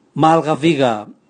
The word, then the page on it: malgaviga: Raga